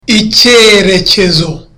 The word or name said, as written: icyerekezo